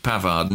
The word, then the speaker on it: Pavard: Pavard.